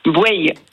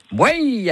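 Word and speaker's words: Bouaye: comment ça on dit ? – Bouaille. – Bouaille.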